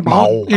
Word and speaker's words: 마오: 스탈린 혹은 모택동 뭐 요즘에 모택동이라는 표현을 잘 안 쓰더라고요. – 마오. 마오. 예, 마오라고 하더라고요. 그 원래 발음을 해줘서 그런 거 아닐까?